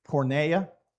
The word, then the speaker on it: porneia: Porneia, E-I is pronounced A in Greek, porneia.